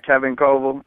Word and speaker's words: Coval: Um, reading a, a, a, quote from Kevin uh, Coval, I think I'm pronouncing his name right. That he's the young Chicago author. – Yeah, uh, Kevin Coval.